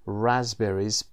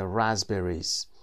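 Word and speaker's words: raspberry: رازبریز پی تلفظ نمیشه دقت داشته باشید، اس هم زی تلفظ میشه رازبریز که تمشک میشه.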